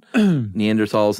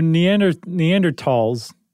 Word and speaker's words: Neanderthal: So Neanderthals, it really, so the correct pronunciation is tall, by the way.